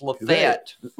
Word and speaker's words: Lafayette: Well, anyways, but I just happened to be watching a video of a guy traveling through some towns in Alabama, and he pronounced it Lafayette. And I'm sorry, locally it's Lafayette. – Lafayette, yeah. The Lafayette Avenue is the road. – No, it's just two syllables, Lafayette.